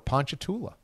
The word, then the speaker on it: Ponchatoula: But for this one, Mraz gets a booski for Ponchatoula.